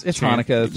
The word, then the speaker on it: Hanukkah: I thought it was Chan. – Yeah, this one says Chanukah. – It's Hanukkah, but it's Hanukkah. – The C is silent.